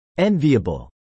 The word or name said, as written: enviable